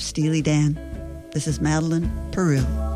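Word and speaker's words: Peyroux: Madeline Peru, which I've been pronouncing Perot, but it's Peru.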